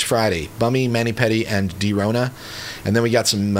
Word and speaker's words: Drona: or Drona. It's D-R-O-N-A. These are some younger band mates from, I think, Hickman. But I think it's pronounced Drona, like it's a play on coronavirus.